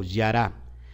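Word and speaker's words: yara (ירה): Y esta viene de la palabra yará Jude Reish Hey, y se, y se, y se pronuncia como yara ¿Y qué significa yara Significa acometer.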